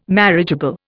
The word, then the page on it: marriageable: Indian